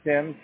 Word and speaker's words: Tim: No, my name is Tim, T-I-M.